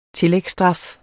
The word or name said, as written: tillægsstraf